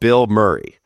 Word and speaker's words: Bilmuri: They're called Bill Murray. The name is spelled B-I-L-M-U-R-I.